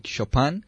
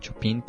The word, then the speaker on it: Chopin: por Frédéric Chopin.